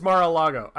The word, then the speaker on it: Mar-a-Lago: It is Mar-A-Lago. I keep thinking Mars.